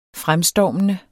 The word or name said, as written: fremstormende